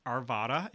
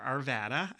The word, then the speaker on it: Arvada: And then Julie, who is listening from Arvada, Colorado, which is pronounced Arvada and not Arvada. I made that mistake before.